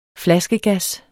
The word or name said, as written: flaskegas